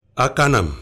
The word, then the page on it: akanam: Efik